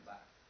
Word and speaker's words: Barthes: Ha scritto Barthes, non so. Sì. Però si legge Barthes.